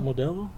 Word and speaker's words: Modelo: 13s of Modelo? How do you guys say that word? Is that how you say it? Modelo? – Modelo? Yeah.